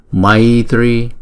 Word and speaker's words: Maitri: Maha Maitri Maitri. Chữ T với chữ R chúng ta đọc là tr tr tr tr tr Maitri Maitri Maitri Ye Maitri Ye.